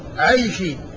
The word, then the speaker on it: العيشي: فالعيشي بالياء والشين، بالياء المثنات والشين، عيشي، وفيه عنسي بالنون والسين، وفيه عبسي بالباء والسين.